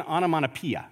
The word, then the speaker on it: onomatopoeia: I don't know if I'm saying it right. Onomatopoeia? English majors? Did I say it right? Okay, good. I'm getting some thumbs up.